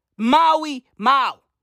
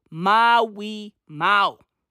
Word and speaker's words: Maui Mau: Maui Mau. Done. Okay? Maui. What's that? Two syllables? Maui Mau. Okay? Hey. My name? Maui. Two syllables. Okay? Mau. One syllable.